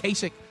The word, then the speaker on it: Kasich: Is that how you say his name? – Is it Kasich? John Kasich. – Kasich? Am I just saying it? Kasich? – Yeah. That's how I say it.